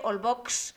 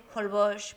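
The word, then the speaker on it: Holbox: Se escribe con H, Olbox, pero se dice Olbosch porque es maya.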